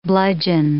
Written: bludgeon

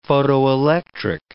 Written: photoelectric

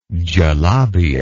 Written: galabia

